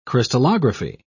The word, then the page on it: crystallography: British English